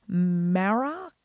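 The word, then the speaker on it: Marach: So we have the kindred of Beor, Marak. I think it's a hard C sound. It's a C-H, M-A-R-A-C-H, Marak.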